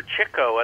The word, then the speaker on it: Chico: However, Chico, and that's the correct pronunciation of his name because he chased chicks.